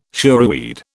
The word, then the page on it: shoreweed: British English